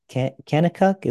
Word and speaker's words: Kanakuk: I always want to pronounce this camp incorrectly, but I believe it's Kanakuk. Is that how you pronounce it? – Kanakuk camps. That's right, in Branson, Kanakuk camps.